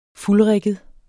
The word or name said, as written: fuldrigget